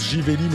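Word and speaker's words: Živeli: Alors Jivelli, ça se prononce Jivelli, mais ça s'écrit avec un Z, Z-I-V-E-L-I.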